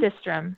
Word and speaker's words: Systrom: Is it pronounced Systrom, the co-founder of Instagram? – Systrom. – Yeah.